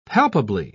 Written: palpably

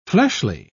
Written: fleshly

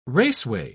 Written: raceway

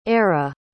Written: Ara

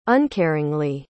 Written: uncaringly

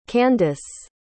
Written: Kandace

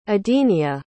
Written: edenia

